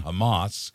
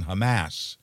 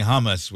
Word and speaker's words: Hamas: I have heard two other pronunciations. There's the American pronunciation, Hamas, and then there's the British pronunciation, Hamas. Hamas. – Yeah, but neither is anywhere close to hummus.